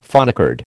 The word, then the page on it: phonecard: American English